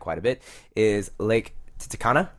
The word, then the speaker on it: Titicaca: Okay, don't pretend that you don't know how to pronounce this word. – I really hope it's not what I think it is. – Every 12-year-old boy knows how to pronounce this Titicaca. – Lake. There we go. – I was really hoping it's something else. – It's not. – Titicaca. – It's Lake Titicaca.